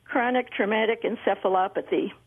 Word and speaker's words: chronic traumatic encephalopathy: And I think that's chronic traumatic encephalopathy.